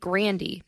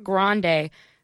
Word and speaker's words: Grande: but she she pronounced it Grandy, not Grande, Grandy.